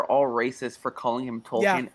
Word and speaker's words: Tolkien: Makes no sense in the UK because his real pronunciation is Tolkien.